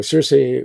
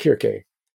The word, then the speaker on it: Circe: Um, the C's are pronounced "cuz" in the original Greek. So Circe was actually pronounced Kirke.